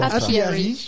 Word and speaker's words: Apiary: Ouais, un jeu qui s'appelle Apiary. Comment donc, tu vas le euh, prononcer en, anglais plutôt Apiary.